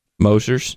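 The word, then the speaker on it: Moser's: And then I'm not sure how to pronounce this, but I think it's Mosher's Austrian Cafe. Mosher's. – No, sounds good to me, man. – Perfect. – All right. It's M-O-S-E-R-S.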